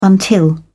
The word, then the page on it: until: British English